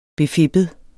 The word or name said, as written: befippet